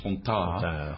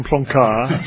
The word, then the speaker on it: Plantard: And I think Plantard, if that's the way you pronounce his name, Plantard. – Rather than Plancard.